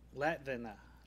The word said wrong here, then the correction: Latvian: Latvine? I don't know. – Latvian. – Latvian? – Latvian.